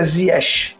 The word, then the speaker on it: Ziyech: Eu estava vendo essa semana assim, é muito debatido né, no, no, nos canais na hora da narração e chegamos à conclusão que a, a pronúncia é Ziyech.